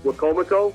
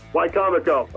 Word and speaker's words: Wicomico: I hope I'm pronouncing this school right. Wicomico? – Close. Wicomico.